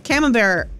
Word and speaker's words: Camembert: C-A-M-E-M-B-E-R-T.